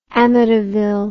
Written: amityville

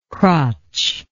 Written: crotched

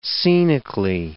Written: scenically

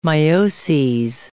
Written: mioses

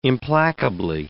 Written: implacably

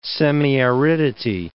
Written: semiaridity

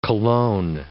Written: colone

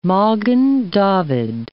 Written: Magen David

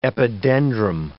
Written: epidendrum

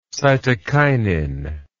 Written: cytokinin

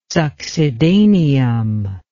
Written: succedaneum

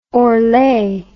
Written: orlet

